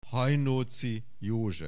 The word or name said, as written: Hajnóczy József